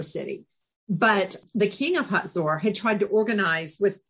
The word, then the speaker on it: Hazor: And so one day we were in Tel HaZor, which, a lot of us, when we read it in the Bible, we say Hazor, but it's pronounced HaZor.